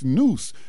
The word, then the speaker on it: Neuse: But it's spelled it's spelled N-E-U-S-E. So I would presume to pronounce that as Noose.